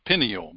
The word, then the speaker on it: pineal: Some people call it a pineal, but it's named after the pine cone because it looks like a pine cone. So we pronounce it pine, pineal.